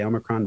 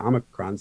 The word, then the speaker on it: Omicron: I am not going to say that there is one correct way to pronounce that. I personally tend to say Omicron. People say Omicron. Both are absolutely fine and acceptable.